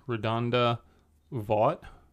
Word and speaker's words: RaDonda Vaught: RaDonda Vaught, if I'm saying that right. RaDonda Vaught? – Sure. – Sure. Fine.